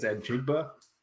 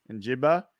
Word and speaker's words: Njigba: He has Will Anderson going to the Seahawks at 5, and JSN, Jackson Smith, how do you say the last name? – It's Njigba. – Njigba, the wide receiver.